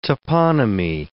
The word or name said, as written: toponymy